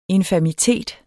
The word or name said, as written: infamitet